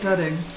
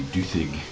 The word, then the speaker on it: Dudding: Well, the name like Dudding. – Yeah. She's a dud. – Actually, if it's a double D, then it's pronounced as a TH. It should be Duthig.